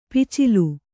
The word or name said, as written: ಪಚ್ಚೋಲೆ